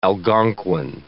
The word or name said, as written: Algonquin